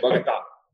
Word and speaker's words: Bogota: Best weather in the world is in Bogota, Colombia. – Oh, you said it correctly. Bogota.